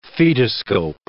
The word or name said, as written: fetoscope